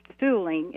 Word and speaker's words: Suhling: It says Vic Sulling, if I have the pronunciation correct, S-U-H-L-I-N-G, Vic's sign, Gas for Less. So that must have been prominent to people happy to find a place where they could tank up there all those years ago. How did you manage to build this sign, or was it rescued? – Okay, the property where our museum used to house Vic Sulling, is how you pronounce it, gas station.